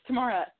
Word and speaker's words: Tamara: Also, it's Tamara.